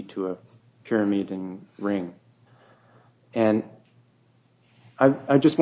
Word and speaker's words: pyrimidine: He's saying how the base, can be purine and a pyrimidine.